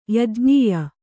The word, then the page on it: ಯಾಜ್ಞೀಯ: yājñīya